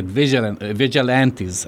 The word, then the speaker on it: vigilantes: So on Monday, it emerged anti-fascist vigilants were naming... you know, I like how they say it, vigilantes, I'm sorry,